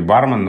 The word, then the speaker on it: бармен: Но в данном случае бармен норма, норма строга и требует от нас ударения на первом слоге.